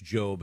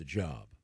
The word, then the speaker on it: Job: No, because that is pronounced Job as opposed to Job.